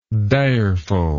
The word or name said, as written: direfulness